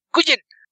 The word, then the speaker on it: Nguyen: por Es vietnamita, se pronuncia Nguyen. Ignora la N del principio, es Nguyen. Dustin Nguyen.